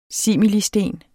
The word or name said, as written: similisten